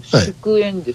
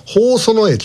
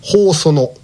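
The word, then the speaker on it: 祝園: これそのままだったら祝宴です。 – はい、祝宴、はい、祝うそのと書いてありますね。これは京都府にあります、 JR 学研都市線の宝園駅です。宝園、宝うその。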